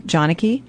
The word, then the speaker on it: Janaki: First name, Janicki, J-A-N-A-K-I. Last name, Severy, S-E-V-E-R-Y.